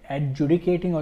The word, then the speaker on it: adjudicating: The framework includes the insolvency professionals, insolvency professional agencies, information utilities, educating authorities, sorry, adjudicating authorities.